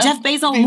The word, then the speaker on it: Jeff Bezos: Jeff Bezos, move the fuck over.